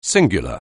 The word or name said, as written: singular